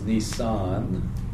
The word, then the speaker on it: Nisan: The month is Nisan. Like the car, I guess.